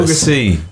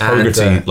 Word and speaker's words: Hogarty: It's Hogarty, like yogurty.